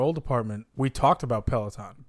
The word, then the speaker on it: Peloton: And we have to discuss the stationary bike company, which I still cannot pronounce, Peloton. – Even though I've been saying it to you for the past 2 weeks. – You know what's funny? – Pelotonin.